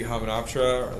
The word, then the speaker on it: Hamunaptra: They see Hamunaptra, or the City of the Dead, or whatever.